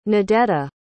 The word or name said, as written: nadetta